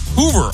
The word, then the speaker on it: Hoover: It's brought to you by Hoover, all services that you need under one roof.